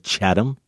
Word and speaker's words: Chatham: Chatham, Mass. I'm going with Chatham. – Yeah. – We always butcher the Massachusetts names, but Chatham, Mass. He says, A-Y-F-K-M, double exclamation points.